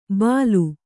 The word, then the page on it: ಬಲು: balu